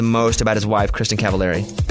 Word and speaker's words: Cavallari: And Kelly's also on season 2 of Very Cavallari.